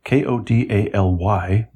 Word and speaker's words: Kodály: Zoltán Kodály, Kodály, but pronounced Kodály, mm-hmm.